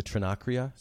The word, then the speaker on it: Trinacria: But I want to read one quote that you have about the Trinacria.